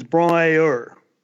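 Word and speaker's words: Bron-Yr-Aur: The correct pronunciation is Brouwer. it's not separate words. It's all one sound. It's Brouwer stop. It's Welsh.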